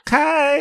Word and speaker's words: Khay: K-H-A-Y. – Okay. – Okay. I would say that like, Kay.